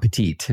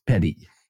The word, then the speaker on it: petit: It's not petite. It's petty. – It's spelled petite, though, and that's your clue. So we pronounce them grand jury and petty jury. – Yeah. – But in fact, it is grand jury and petit jury. And it's taken from French.